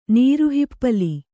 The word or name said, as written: ನೀರುಹಿಪ್ಪಲಿ